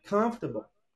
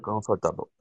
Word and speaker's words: comfortable: Tipo assim, ó, tá escrito lá, confortable, e eles falam comfortable, comfortable. Comfortable. Very good. Comfortable. – Comfortável.